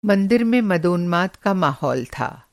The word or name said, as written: मंदोन्माद